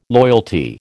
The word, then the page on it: loyalty: American English